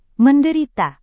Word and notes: menderita: Indonesian

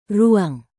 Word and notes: ruang: indonesian

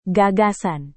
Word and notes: gagasan: Indonesian

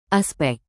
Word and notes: aspek: indonesian

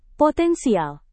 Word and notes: potensial: Indonesian

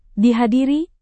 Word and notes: dihadiri: indonesian

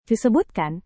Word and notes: disebutkan: indonesian

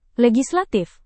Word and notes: legislatif: indonesian

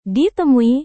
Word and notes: ditemui: Indonesian

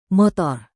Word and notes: motor: Indonesian

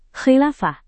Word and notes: khilafah: indonesian

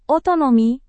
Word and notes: otonomi: Indonesian

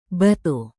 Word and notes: batu: indonesian

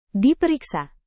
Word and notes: diperiksa: indonesian

